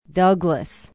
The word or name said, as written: Douglas